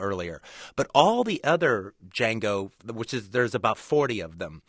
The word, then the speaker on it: Django: And the kind of catchphrase, the trademark phrase from the movie, somebody asks him in his name, and he says, "Django, the D is silent."